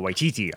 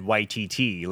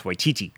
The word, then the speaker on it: Waititi: Taika Waititi. I heard him say his name once. I'm trying to figure out how to, because I always said Waititi, like mm-hmm. Waikiki, mm-hmm. Hawaii, but it's Waititi.